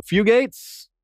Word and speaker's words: Fugate: Shout out to Richard of House York on Twitter, who sent us a link to the Blue Fugates. Did I say that right? – Yeah, Fugate. – The Blue Fugates. Look up the Blue Fugates. It's spelled F-U-G-A-T-E-S, F-U-Gates, one word, F-U-Gates.